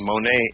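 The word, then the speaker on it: mone (μονή): We would spell it M-O-N-E, mone.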